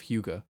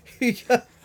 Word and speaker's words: Hygge: No, I didn't say Hygge. – Not Hygge. – Hygge. I think you're mispronouncing it there.